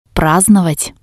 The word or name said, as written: prasdnovat